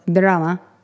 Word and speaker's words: Drama: which is the region of Drama. It sounds exactly like it's spelt, or Drama, as they would say.